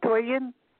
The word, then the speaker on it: Toyin: Okay, it's T-O-Y-I-N. So T as in Toyin. – And you pronounce it Toyin? – Yep. – Toyin?